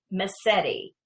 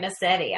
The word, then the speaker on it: Mascitti: Well, our first guest is a country singer-songwriter, Precious Lauren Massetti. Am I saying that right, Massetti? – Yes, you are.